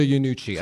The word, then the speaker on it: Iannucci: So the creator of Veep, Armando Iannucci, am I saying that right? – Yeah, Armando Iannucci.